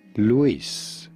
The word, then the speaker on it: Luis: How do you go about pronouncing it? Luis. In Spanish, there's generally an accent on the I, meaning you do want to stress on the last part of the word, the is part of the word. Luis. Luis. But apart from that, it's very easy, just like Luis. Just say Luis.